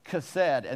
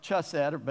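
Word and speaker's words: chesed (חסד): But it's spelled C-H-E-S-E-D, chesed. And that word, or chesed, but chesed probably has a lot more guttural in there.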